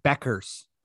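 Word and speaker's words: Bueckers: And one of the top Google searches was, how do you pronounce Paige Buchers name? And it looks like it's Beckers.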